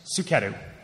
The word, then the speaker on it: Suketu: Suketu. – Spell that for me. – S-U-K-E-T-U.